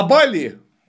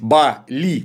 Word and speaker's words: Бали: На Бали, или, как правильно говорить, Бали, там все слоги ударные, крис обычно ассоциируют со змеей, ну, понятно, ну или с драконом, потому что он ползет.